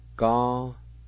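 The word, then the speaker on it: ganelv: Ga-ne-la.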